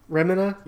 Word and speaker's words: Remina: I guess another example in his works, which was, it's kind of similar, have you ever read Retina? – Remina? – Sorry, I'm saying it wrong.